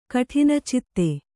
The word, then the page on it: ಕಠಿನಚಿತ್ತೆ: kaṭhinacitte